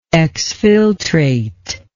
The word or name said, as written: exfiltration